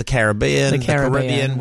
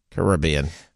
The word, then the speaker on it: Caribbean: Rib? Put rib in capitals? – Yeah. – Or Caribbean. – Bean in capitals. – Put bean in capitals.